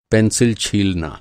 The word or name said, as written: छीलना